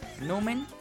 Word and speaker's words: numen: Sí, numen con acento en nu, numen.